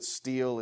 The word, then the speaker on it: stele: I know that word looks like stele, but you pronounce it steel.